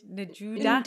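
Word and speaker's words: 'nduja: Nduja. – Nduja.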